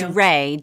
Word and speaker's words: Wray: Can I, very quickly, I looked up how to pronounce her last name in Hindi, and it's Ray, W-R-A-Y, pronounced like W-R-A-Y, Ray.